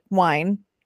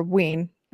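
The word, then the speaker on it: Wein: I'm not sure if it's Vine or Wine. Or Ween or Veen. I'm not sure. Oh, you know what? It's right here. It says. – Wow. – Yeah. The problem is that I can't actually read a pronunciation guide. I think it's Win. Elizabeth E.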